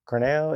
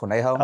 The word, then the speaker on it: Corneo: Or no, I think it's just pronounced Corneo. – Okay. – But in American Corneo. People just call me Corny Joe because they say every letter in the name, so it just sounds weird.